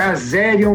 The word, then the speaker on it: Azerion: Também o Country Manager da Azarium falei certo, Azerion. Falou perfeito. É Azerion mesmo.